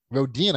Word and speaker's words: Rodian: He's stuck there with this Rodian. Rodian, I think I'm saying that right.